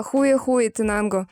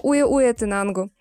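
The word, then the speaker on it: Huehuetenango: У нас здесь буквально через речку начинается а, соседний департамент, который, если открыть Google Maps, а, в Google Maps он называется Хуе-Хуе Тенанго. Но по-испански ачи не читается, то есть буква ачи. Поэтому уе-уе Тинангу.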